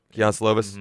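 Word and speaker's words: Kedon Slovis: And Keon Slovis, did I say that right? – I think so. – Keon Slovis, mm-hmm.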